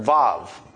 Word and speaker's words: Vav: Well, they're just mechanically translating the Hebrew letter Vav. Or wow, or depending on if you want to use the old way of pronouncing it.